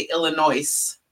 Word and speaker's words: Illinois: Or people who say Illinois. The S is silent.